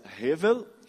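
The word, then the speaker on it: hevel (הבל): Vaidade, meus irmãos, no hebraico, é uma palavra que se pronuncia hevel, e é a mesma palavra que eles utilizam na Bíblia para se falar do vapor, da fumaça, da neblina.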